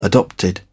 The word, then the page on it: adopted: British English